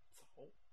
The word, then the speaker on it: Tsao: The king, Sao, I don't know how to say this, T-S-A-O. – Sao. – Sao. Is that it? – Yeah, Sao.